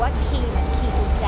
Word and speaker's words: Quay: QUAY แต่คำนี้อ่านว่าคีย์เหมือนคีย์กุญแจละค่ะ